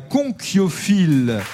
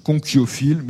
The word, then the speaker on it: conchyophile: Ça s'écrit C-O-N-C-H-Y-O-P-H-I-L-E. – C'est la conchiliculture. – La conchiliculture, la conchiliculture, c'est ça. Conchiophile, mais je crois qu'on dit que, hein, on dit conchiophile. Enfin bon, on accepte les deux.